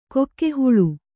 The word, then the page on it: ಗೋಗಹಳೆ: gōgahaḷe